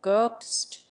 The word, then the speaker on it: ghost: In English, there is a very strange word called ghost. – 你看它拼写 ghost Look, it's spelled ghost. – 中间那个 h 是干嘛用的？不发音啊。 – What is the purpose of the H in the middle? It's not pronounced.